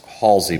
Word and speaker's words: Halsway: We've been here for a week and the main event that's coming up is this thing at Halsway Manor, pronounced Halsey, but spelled H A L S W A Y.